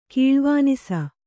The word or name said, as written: ಕಲ್ವಾನಸ